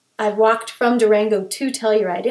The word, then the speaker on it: Telluride: including the Telluride Mushroom Festival.